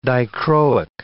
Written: dichroic

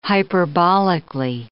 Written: hyperbolically

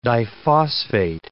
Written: diphosphate

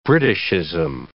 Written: Britishism

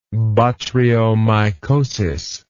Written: botryomycosis

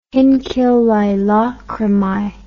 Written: hinc illae lacrimae

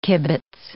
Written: kibbitz

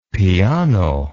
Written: Peano

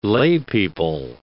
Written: laypeople

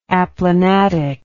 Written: aplanatically

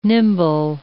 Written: nimble

